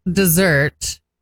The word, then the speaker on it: Desert: Mount Desert is French and you pronounce it that way. Otherwise, it would be... Otherwise, it would be Desert Mountain. – Actually, the T is silent if you're going to really speak French.